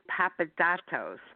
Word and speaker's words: Papadatos: That's Marcos Papadatos, and I'll spell it as well. M-A-R-K-O-S P-A-P-A-D-A-T-O-S, because I had to really work on the pronunciation.